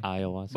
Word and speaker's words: ayahuasca: Ayahuasca. – But ayahuasca. – White people want it sound. – It's A-Y-A-H. Ayahuasca. – Oh. – Yeah. – Well, okay. – And you don't pronounce the H.